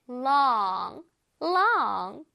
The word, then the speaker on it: long: 자, 첫 번째 단어는 long. long. 긴이라는 뜻이에요. 이 단어는 우리가 많이 알죠. 저 추러스가 길이가 어때요? 굉장히 길죠? 한번 발음해보겠습니다. long. long 할 때 롱롱롱이 아니라 혓바닥이 살짝 나와야 되겠죠. L이니까 long long.